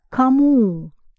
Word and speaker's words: кому: And to whom becomes кому. Кому, which rhymes with ему.